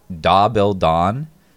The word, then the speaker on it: Dahbeldahn: but it's Da Bil Don. It's another KFC ripoff, but it's spelled D-A-H-B-E-L-D-A-H-N.